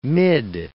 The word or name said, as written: mid